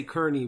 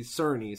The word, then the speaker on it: Cerny: I used to say Kearney, but I looked it up and they say Cerny, so I'll say it like that.